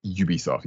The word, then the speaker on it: Ubisoft: Is it Ubisoft? – Ubisoft, yes.